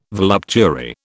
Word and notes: voluptuary: British English

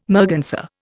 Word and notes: merganser: Indian

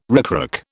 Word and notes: ricrac: British English

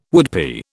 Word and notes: woodpie: British English

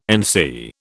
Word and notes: NC: British English

